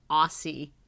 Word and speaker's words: Aussie: A-U-S-S-I-E? – Mm-hmm. – I was pronouncing it Aussie. It's apparently Aussie. Like Osborne. That's how you pronounce that.